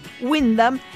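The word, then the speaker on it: Wyndham: Windham y podés comprar noches de hoteles para usar hasta diciembre del 22, 2022 el año que viene y el otro.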